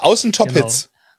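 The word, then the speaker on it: Außen Top Hits: Den Titel liebe ich, ja, des Studioalbums Außen Top-Hits, Innen Geschmack, ja, finde ich mega, ja. Also die Betonung musst du vielleicht nochmal anders, sind Top-Hits. – Ja, Außen Top-Hits.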